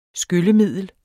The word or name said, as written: skyllemiddel